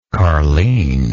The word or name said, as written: carleen